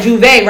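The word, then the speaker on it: J'Ouvert: I said it right, Juve, right? – Yeah. – Juve.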